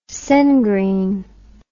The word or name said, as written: sengreen